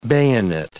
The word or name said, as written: bayonet